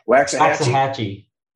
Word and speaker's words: Waxahachie: How does that pronounce? Waxahachie. What the? It's a strong X.